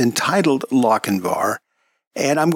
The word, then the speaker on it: Lochinvar: That was Gunsmoke, and the name of that episode was Lochinvar. And it's Lochinvar. I think I mispronounced it at the beginning of the show.